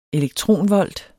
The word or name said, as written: elektronvolt